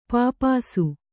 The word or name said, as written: ಪಿಪಾಸೆ